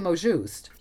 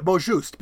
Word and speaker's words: mot juste: I would say mot juste. – Yeah, mot juste, because the E tells you that you're going to pronounce the T. So it's mot juste.